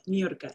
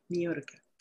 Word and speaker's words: Nirka: Yes, you say it right. Nirka, like New York. Nirka. – New York. – Nirka.